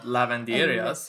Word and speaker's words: lavanderias: And even for, like, our laundry, there are these things in Mexico called lavanderias. Did I say that right? – Yeah, you lavanderia. Did.